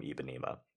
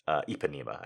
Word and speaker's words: Ipanema: Ibanima. Ipanema, I guess I should pronounce it. I've always dropped the P, which, you know, like right into a toilet bowl, you know?